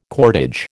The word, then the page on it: cordage: American English